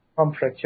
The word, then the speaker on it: Pomfret: Is that how you say it? Pomfret? Pomfret? – Pomfret, yeah.